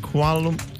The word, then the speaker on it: Kuala Lumpur: Is it Sarah, is it Kuala Lumpur? – Yeah, you said it right.